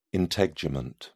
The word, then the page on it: integument: British English